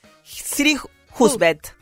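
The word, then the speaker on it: Siri Hustvedt: Pasó por Dale Fab, Siri Hufstein. – Siri Husbet.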